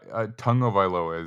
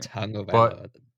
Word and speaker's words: Tagovailoa: But yeah, Tagovailoa is how you say his last name. – Tagovailoa.